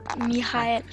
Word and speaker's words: Michael: Michael, he's German, so it should be Michael Ende or Michael. – Michael.